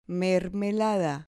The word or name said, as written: mermelada